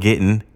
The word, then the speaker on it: Gittin: I'll spell it for you, M-I-S-H-N-A-H. Second word, Gittin, G-I-T-T-I-N. I'll spell it again, M-I-S-H-N-A-H-G-I-T-T-I-N.